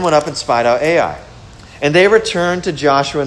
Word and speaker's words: Ai: Now Joshua sent from Jericho to Ai, that's how it's pronounced, Ai, which is near Beth Avon, east of Bethel, and said to them, go up and spy out the land.